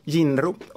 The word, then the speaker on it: Jinro: Nej, det är en Jinro heter varumärket. – Jinro? – Jinro.